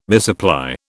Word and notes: misapply: British English

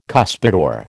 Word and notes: cuspidor: American English

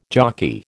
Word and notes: jockey: American English

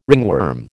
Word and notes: ringworm: American English

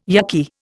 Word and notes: yukky: Indian

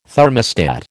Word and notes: thermostat: American English

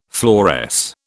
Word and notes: fluoresce: British English

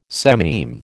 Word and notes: sememe: American English

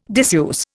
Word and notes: diseuse: Indian

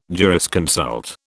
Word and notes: jurisconsult: British English

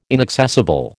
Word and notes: inaccessible: American English

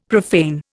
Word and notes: profane: Indian

